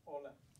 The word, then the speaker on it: Hola: Hola. – Hola, but it has an H, no? – It has an H. – Why do you say the H? – Silent. – It's a silent H, right?